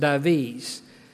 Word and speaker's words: Dives: It's spelled actually D-I-V-E-S, but it's pronounced Davies.